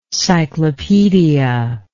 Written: cyclopaedist

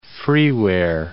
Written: freeware

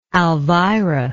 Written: alvira

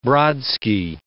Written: Brodsky

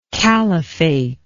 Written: khalifate

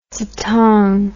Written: dzong

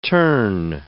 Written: terne

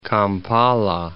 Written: Kampala

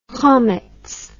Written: chometz

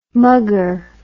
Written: muggar

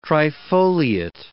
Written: trifoliate

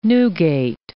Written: Newgate

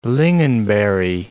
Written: lingonberry

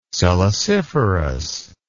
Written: siliciferous

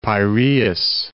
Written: Piraeus

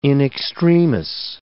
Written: in extremis

